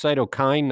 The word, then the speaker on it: cytokine: And it's pronounced cytokine, not cytokine.